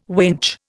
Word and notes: winch: Indian

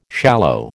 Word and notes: shallow: American English

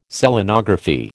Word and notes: Selenography: American English

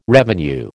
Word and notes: revenue: American English

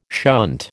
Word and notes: shunt: American English

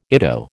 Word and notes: ITO: American English

